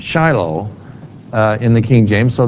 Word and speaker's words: Shiloh: Now in the King James Version, you'll see the word Shiloh, or Shiloh, as some would pronounce it.